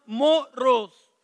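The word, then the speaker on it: moros (μωρός): And the word foolish here in the Greek is pronounced moros. Moros. Which we get the word from moron. That's where we get the word moron.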